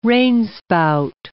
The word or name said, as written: rainspout